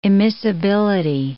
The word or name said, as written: immiscibility